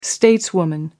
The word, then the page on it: stateswoman: American English